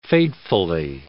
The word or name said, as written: fatefully